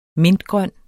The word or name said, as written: mintgrøn